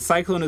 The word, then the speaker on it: Psyclone: And I should clarify that Cyclone is spelled P-S-Y-C-L-O-N-E, as in a little bit of psycho and a little bit cyclone.